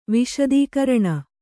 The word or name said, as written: ವಿಶದೀಕರಣ